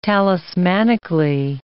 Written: talismanically

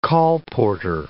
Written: colporteur

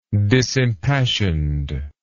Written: disimpassioned

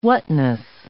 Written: whatness

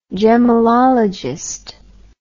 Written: gemellologist

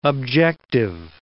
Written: objective

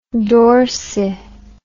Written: dorsi

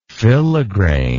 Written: filigrain

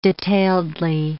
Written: detailedly

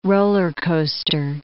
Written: roller coaster

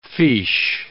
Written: fiche

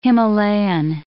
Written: Himalayan

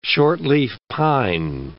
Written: shortleaf pine

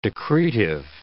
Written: decretive

